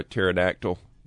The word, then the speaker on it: pterodactyl: The P is silent. Yeah, it's like why you can't hear a pterodactyl go into the bathroom, because the P is silent.